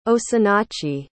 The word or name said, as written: osinachi